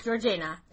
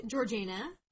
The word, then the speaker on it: Georgiana: So almost immediately after they arrived, Georgiana, which I actually wrote out phonetically. – Did you really? – Right here. George, and then I wrote A-Y-N-A.